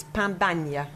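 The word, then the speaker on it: pan bagnat: How are we pronouncing this? – I think it's pan banya.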